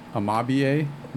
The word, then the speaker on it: Amabie: We got a Japanese spirit amabie.